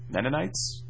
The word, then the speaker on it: Mennonites: Mennonites? Mennonites. I did say that right.